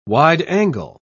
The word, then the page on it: wide-angle: British English